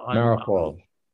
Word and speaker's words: Mariupol: Mariupol.